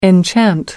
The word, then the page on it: enchant: American English